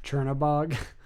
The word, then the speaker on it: Chernobog: Chernobog.